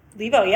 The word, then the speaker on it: Levo: What are you inviting in for the, and I don't want to mispronounce this, Levo? – Levo, yeah, Levo? You got it.